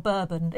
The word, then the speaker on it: Bourbon: Is that how you say it? Bourbon or Bourbon? It's Bourbon. – Bourbon. – I think Bourbon's the biscuit. – Bourbons are the biscuits. Oh. Bourbon is the booze.